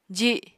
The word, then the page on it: ji: apatani